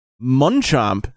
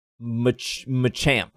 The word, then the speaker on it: Machamp: She called Machop Munchomp this time, which is a new pronunciation from the last time when she called it Manchomp. – I think she was talking about Machamp, actually. I think she was excited she has a Machamp now.